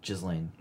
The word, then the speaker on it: Ghislaine: It's Ghislaine. – Ghislaine. Maxwell. – That's what we'll go with. – And prison officials want to ensure Ghislaine Maxwell doesn't meet the same fate as Epstein.